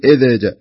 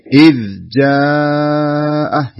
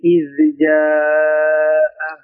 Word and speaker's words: إذ جاء: شوف لا تقول إذا جاء إذا جاءه هذا قلقلة في الذال الصحيح إذ جاء يعني انتبه لا تفلت منك الذال وتصير فيها شيء من القلقلة حاول تتحكم فيها بحيث أنك تنطقها ذال ساكنة بدون ما يكون فيها يعني شيء من القلقلة حتى لا تتحرك من مكانها فتقول بالصدق إذ جاء سمعنا كذا وكذب وكذب بالصدق إذ جاء.